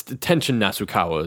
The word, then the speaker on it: Tenshin Nasukawa: And it's Tenshin Nasukawa is his name.